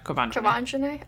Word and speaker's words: Quivenzhané: Since Courtney's interview, the Twitter users have unearthed more of Chrissy's past controversial tweets that targeted Lindsay Lohan, Quivenzhané Wallace. Did I say that right? – Mm-hmm. – I think I'm not saying that right. – That is correct. Quivenzhané.